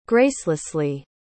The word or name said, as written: gracelessly